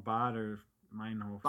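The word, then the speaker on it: Baader-Meinhof: I don't know, Bader, Meinhof. – Bader, Meinhof. – You're pronouncing it in German. – There's going to be an accent. – You gotta say it with some flair.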